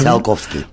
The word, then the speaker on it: Tsiolkovsky: Al principio de tu libro sacas una cita de Constantín, yo no sé cómo se pronuncia esto, Tsiolkovsky. ¿Lo he dicho? – Tsiolkovsky. – Vale.